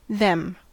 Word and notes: them: American English

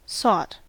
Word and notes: sought: American English

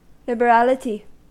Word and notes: liberality: American English

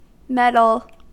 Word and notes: meddle: American English

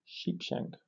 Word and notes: sheepshank: British English

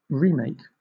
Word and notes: remake: British English

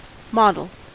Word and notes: model: American English

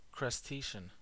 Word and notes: crustacean: American English

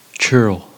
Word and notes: churl: American English

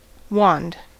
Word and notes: wand: American English

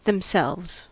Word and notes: themselves: American English

